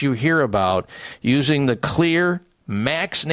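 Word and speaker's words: Xlear: Well, there is a way that you can help clear it out with a company by the name of Clear, but Clear is spelled with an X. X-L-E-A-R, the X in their name stands for xylitol, one of the important components in the Clear Max Nasal Spray.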